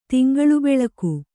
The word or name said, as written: ತಿಂಗಳುಬೆಳಕು